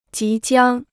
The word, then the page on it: 即将: jí jiāng